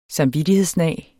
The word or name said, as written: samvittighed